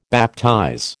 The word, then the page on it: baptize: American English